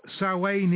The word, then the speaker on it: Samhain: Samhain is the correct pronunciation, that's correct.